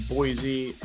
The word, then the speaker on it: Boise: You're saying Boise right. You did it. Hey, there is a little bit of your Eastern. The Z slips in at times, but you're close. Like you're really close to saying it right.